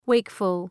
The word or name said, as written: wakeful